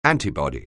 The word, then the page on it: antibody: anglais